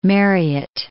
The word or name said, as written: Marryat